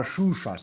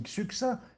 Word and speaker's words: Xuxa: Xuxa, là, la là, là, là, qui, qui vient Rio du Hein Choucha Mais non, pas Choucha, c'est Xuxa, ça s'écrit avec un X. On dit Xuxa. Si c'était Choucha, ça s'écrirait C-H-O-U-C-H-A, enfin.